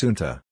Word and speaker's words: junta: One of the worst examples of this mistake is the Spanish word junta that referring to a military regime. It is pronounced junta.